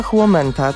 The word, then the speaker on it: huomenta: Mam potwierdzenie, chyba huomenta, tak wymawia się fiński dzień dobry,